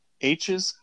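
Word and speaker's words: Aech: Oh, it's A-E-C-H. – Yeah. And was it Ash or was it H? I can't remember. Yeah, it's H. Like, literally the letter H.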